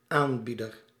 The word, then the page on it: porteur: [pɔʁ.tœʁ]; France